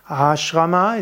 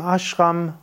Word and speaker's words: Ashram: Ashrama ist der Sanskrit-Ausdruck und Hindi sagt man dann eben Ashram und da Hindi die nordindische Sprache ist, spricht man meistens von Ashram, auch die Südinder und die indischen Meister sagen auch Ashram.